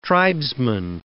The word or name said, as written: tribesman